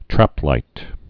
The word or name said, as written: traplight's